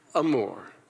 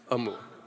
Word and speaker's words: amor: Amor. Everybody say amor. Do you have to roll your R? Amor. No, you don't on that one, do you? You do? Say it again. Oh, yeah. Amor.